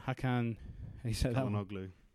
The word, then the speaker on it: Çalhanoğlu: Hakan Çalhanoğlu.